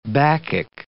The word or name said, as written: bacchic